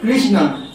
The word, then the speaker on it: Krishna: And Krishna name that na, murdha, not this. Touching the And another thing, three dots, not Krishna, K-R-S-N-A.